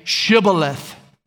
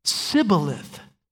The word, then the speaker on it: Shibboleth: We're told, and he, that is the disguised Ephraimite who was fleeing the battle, he would say Sibboleth, for he could not pronounce it right. In other words, the native Ephraimites couldn't say the shush sound.